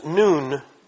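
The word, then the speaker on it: noon (νῦν): And here the Greek word is noon, nu epsilon nu, pronounced like high noon, and it means now.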